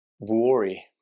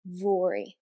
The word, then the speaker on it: Vuori: I'm so glad that you've pronounced that in the way that I've always questioned how it's supposed to be pronounced. So Vuori. – Vuori. Yeah, it's a tricky one.